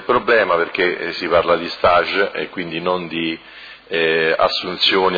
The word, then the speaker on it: stage: Allora, questa mozione è oggetto stage formativo, si pronuncia in francese questa parola, quindi stage formativo,